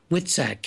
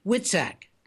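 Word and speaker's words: Witczak: Her name is Kim Witsak. Kim, am I pronouncing your last name right? – You are. Good afternoon. – Good afternoon. Witsak. Let me spell it for the world. W-I-T-Z. W-I-T-C-Z-A-K. W-I-T-C-Z-A-K. Witsak.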